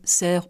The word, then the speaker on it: cerf: Alors, je me suis renseignée, donc toutes les deux prononciations sont possibles, C-E-R-F, cerf au cerf.